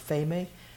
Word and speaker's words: pheme: Well, that's where the fame, P-H-E-M-E, pronounced fame, which means rumor or evil speaking.